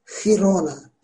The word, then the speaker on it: Girona: Girona bir şehirdir İspanya'da. G-I diye yazılır ama Girona diye okunur.